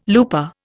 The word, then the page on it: looper: Indian